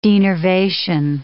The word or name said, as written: denervation